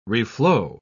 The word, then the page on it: reflow: British English